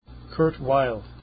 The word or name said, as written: Weill